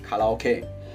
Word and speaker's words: karaoke: Because, you know, karaoke, and you know, correct pronunciation in Japan, we call it karaoke.